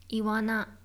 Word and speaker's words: iwana: Iwana.